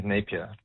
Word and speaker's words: Napier: My surname is Napier. That's N-A-P-I-E-R.